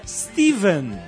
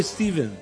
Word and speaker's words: Stephen: A grafia PH é apenas uma versão antiga do nome escrito com V, Steven. A pronúncia nos dois casos é a mesma, com V.